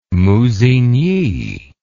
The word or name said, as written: Musigny